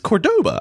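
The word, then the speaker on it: Córdoba: But, like, it's Cordoba. I've heard that name. – I've seen that name, Cordoba? – Too. I've heard Cordoba, which doesn't make any sense. – That's not... That's like the Polish pronunciation of his name or something. Anyway. – Accent on the first O.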